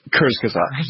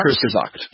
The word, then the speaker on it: Kurzgesagt: Kurzgesagt. – Blumenkratz? – Kurzgesagt.